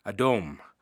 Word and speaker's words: Adom: Edom is derived from a Hebrew word meaning red, Adom. It's not like Adam, which is spelled A-D-A-M, it's A-D-O-M.